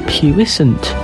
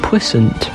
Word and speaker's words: puissant: This week's word is puissant, which is also pronounced puissant. It is spelled P-U-I-S-S-A-N-T.